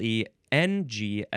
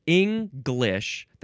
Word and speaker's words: English: It's English. That's one pronunciation. And the other pronunciation has no G sound at all.